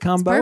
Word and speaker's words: Combow: Paulina Combow, is that how you pronounce it? Combow? – It's perfect, yeah.